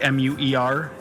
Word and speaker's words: Muer: I would say you're pronouncing it correctly. M U E R. Muer.